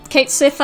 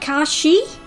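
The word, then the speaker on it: Cait Sìth: says that Kate Sith actually should be pronounced something like Kashi, because Scots Gaelic is weird.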